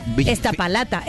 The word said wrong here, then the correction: Iztapalapa: O es Estapalapa, a ver. – Estapa. – ¿De dónde son ellos? – De Iztapalapa. – ¿Cómo se escribe? – Iztapalapa, con Z.